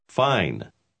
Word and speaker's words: fine: Fine. – ¿Notó la letra E al final de esa palabra? La E final suele cambiar el sonido de la vocal precedente, pero la E misma no se pronuncia.